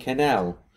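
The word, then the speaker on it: Quesnel: There's a silent S in her surname, which I'm going to transpose to her first name, and from this point forward called her Spooky Cannell.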